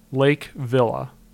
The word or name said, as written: Lake Villa